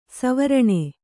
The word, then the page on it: ಸ್ವರ್ಣ: svarṇa